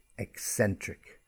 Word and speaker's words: eccentric: Next word, eccentric, just with the pronunciation, ec, ec like almost like egg, ec, eccentric.